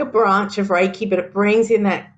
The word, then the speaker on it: Reiki: So Reiki.